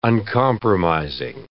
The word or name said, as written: uncompromising